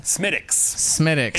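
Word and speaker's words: Smithwick's: Smittix. It's spelled Smithwicks.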